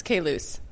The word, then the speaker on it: Caylus: I think the way I always look at it is just it's the letter K and then loose. So it's Caylus.